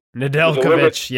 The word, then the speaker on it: Nedeljkovic: Nedeljkovic.